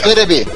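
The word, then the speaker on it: Terebi (テレビ): Coisa semelhante acontece com Passocon, que vem de personal computer, Noto, de notebook, Teberi, de televisão, Não, etc. Terebi. Terebi. Terebi.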